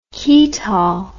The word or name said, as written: ketol